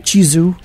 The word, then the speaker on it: Chizu: Chizu.